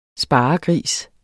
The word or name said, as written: sparegris